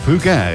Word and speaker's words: Fugain: En dat is eentje van Michel Fugain. – Fugain, ja, goed uitgesproken.